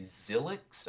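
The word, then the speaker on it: Xylyx: So this is Earthly Science in Star Wars, and this is posted by Zylix, I think is how you say it. It's X-Y-L-Y-X.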